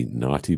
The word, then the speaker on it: Nauti: the name of it is going to be Naughty by Nature with Nature Boy. And nice. Nauti is spelled as N-A-U-T-I as in nautical.